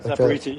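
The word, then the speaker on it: Zaporizhzhia: Yes, Zaporizhia, something like that. – Zaporizhia.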